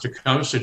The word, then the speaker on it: Tecumseh: Well, you've got the anglicized form of Tecumseh just right. – Wonderful. – Supposedly, I mean, as best I could determine, the original pronunciation in Shawnee was Tecumseh. And I think it became Tecumseh because Americans and British alike didn't particularly care for that th sound at the end or find it easy to pronounce.